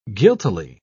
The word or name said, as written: guiltily